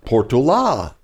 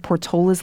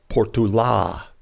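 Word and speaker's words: Portolá: He had an accent at the end of his name, so the name should be pronounced Portula. – Finally, how he would have pronounced it, with the accent and stress on the A.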